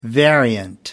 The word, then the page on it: variant: American English